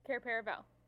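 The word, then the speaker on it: Cair Paravel: Care Paravel.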